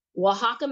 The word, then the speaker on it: Oaxaca: Oaxaca, Mexico.